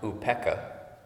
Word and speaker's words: upekkha: And in the Pali language, it's pronounced upekkha. And I just like that word, I don't know why. U-P-E-K-K-A is the way I spell it.